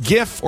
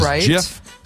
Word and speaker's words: GIF: Is it a hard G? Even the creator of it said, I prefer the soft G as in ginger. It is JIF.